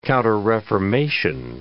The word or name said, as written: counterreformation